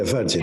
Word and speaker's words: Virgin: Yeah, Virgin.